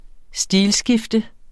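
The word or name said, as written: stilskifte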